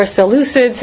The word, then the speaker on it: Seleucids: and the empire of the Seleucids, or Seleucids, how some people pronounce it, in the north, Syria, Iran, Iraq, that whole area.